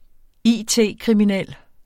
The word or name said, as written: it-kriminel